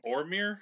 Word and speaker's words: Orm: Orm rhymes with Norm.